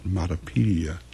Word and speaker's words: Matapedia: and their latest album is called Matapedia.